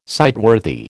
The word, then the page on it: sightworthy: American English